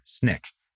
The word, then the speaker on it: SNCC: He was at this time a member of the SNCC, Student Nonviolent Coordinating Committee, or SNCC, as it was often pronounced.